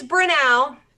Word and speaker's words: Brenau: Yes, it is Brenau.